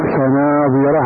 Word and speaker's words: فناظرة: فناظرة على اللفظ، باللفظ تنطقها فناظرة، فالرسمي بحذف هذه الألف، ولهذا قال في اللفظ، وبعضها بألف على اللفظ فإنك تنطقها فناظرة بألف، وأما في حال رسمها فتجد أنك مصحف محذوفة فناظرة، ألف محذوفة بين النون والظاء.